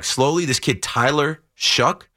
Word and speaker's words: Shough: Remember the name, Tyler Shuck, but pronounced like pronounced Shuck, but spelled show, like though, but S-H-O-U-G-H. Tyler Show out of Louisville.